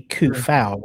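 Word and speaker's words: Coufal: When it comes to Soukek, so it should be Koufal rather than. Because he's the first three letters are spelled the same, isn't it? So C O U rather than S O.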